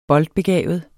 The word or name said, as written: boldbegavet